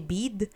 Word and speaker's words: Bede: Anyway, weirdly enough, ang favorite character ko ay si Bid? But I know yun dapat yung pronunciation, pero I like calling him Bede. Kasi B-E-D-E, Bede, Bede wow.